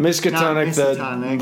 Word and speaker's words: Miskatonic: Speaking about this Miskatonic beer, did I say that right? Miskatonic. – Miskatonic, the Lovecraft reference.